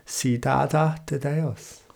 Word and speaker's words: Cidade de Deus: Or I'm going to go the Brazilian Portuguese pronunciation of it, Cidade de Deus.